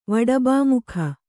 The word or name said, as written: ವಡಬಾಮುಖ